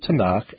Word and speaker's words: Tanakh: And that said, that's an acronym, and you pronounce it Tanakh, but Christians call it and are familiar with it as the Old Testament.